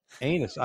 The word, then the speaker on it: Enes: Enes. I mean, that's how I'm pronouncing his name.